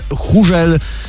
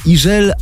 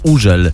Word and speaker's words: Hugel: Hugel. No więc tak jest właśnie nieprawidłowo, a za to prawidłowo jest Ijel albo Użel, bo on jest Francuzem i we francuskim to H jest nieme.